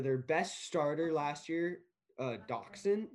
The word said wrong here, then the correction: Dotson: Doc, Doc, Doc, Son, Dotson. – I don't know how to pronounce it, Dotson.